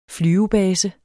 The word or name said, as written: flyvebase